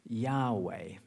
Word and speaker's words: Yahweh: In the original, it actually spells something like YHWH. I don't know how you pronounce that, you know, but we've anglicized it to Yahweh. You might have heard that name, right? Yahweh, which is God's personal name.